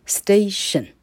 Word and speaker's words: station: 所有的 t i o n 这样的写法，t i o n 这一个节念作 shen 啊，t 哈念作 sh，那 i o 一起念呃哈，那 n 念作嗯哈，所以你要一个一个的学，于是三个 k k 凑起来叫做 shen。省哈，火车站 station。